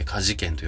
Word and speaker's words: 歌志軒: えー、家事券はですね、歌に志に剣は軒の剣と書いて家事んと読むんですけれども、あの、こちら、油そば、アプ、アラそば。あの、要するにそのスープのない麺ですね。